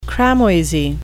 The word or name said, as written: cramoisy